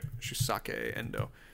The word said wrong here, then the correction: Shusaku Endo: Shusaku Endo. Shusaku Endo.